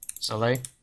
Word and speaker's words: Soleil: Soleil. – Yeah, like Cirque du Soleil. – Is that actually how it's pronounced? – Yeah.